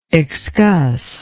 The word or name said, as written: excuss